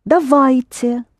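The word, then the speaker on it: Davajte (давайте): Dvajeti. – Davajte. A will be accented. Davajte. Pronounce it again, please. – Dvajeti. – Yes, davajte.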